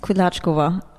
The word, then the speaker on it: Kudlačková: Also eigentlich ist es Kudlačková, ja. Aber mit dem einen Deutschen haben sie die ganze Familie das Ova weggenommen und alle heißen Kudlacek.